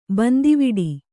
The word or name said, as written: ಬಂದಿವಿಡಿ